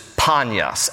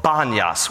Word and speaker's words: Panias: But the Arabs can't, in Arabic language, they cannot pronounce P. Panyas. They can't say the letter P, so it is called Banyas with a B.